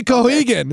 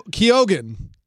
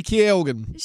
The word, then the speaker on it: Keoghan: All right, Barry Keoghan. Did I say that right? I don't think so. – I don't think you did, but now I'm blanking on how to actually say it. – Keoghan. Barry Keoghan.